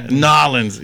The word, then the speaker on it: New Orleans: It's spelled new. – You know, like not nah, N-A-W. New Orleans sounds like you're about to gnaw on somebody's body part.